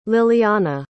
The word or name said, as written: lilyanna